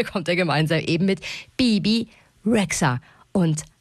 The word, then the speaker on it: Bebe: My name is Bibi, like two B's, not Bebe, Bibi. – Ja, also den Nachnamen dieser Bibi spricht man zum Glück einfach so aus, wie man ihn schreibt.